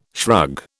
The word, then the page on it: shrug: British English